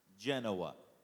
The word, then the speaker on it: Genoa: But apparently Americans can't pronounce Genoa, so it's precisely. I've already slaughtered it. How does it actually pronounce, Pastor? – Genoa.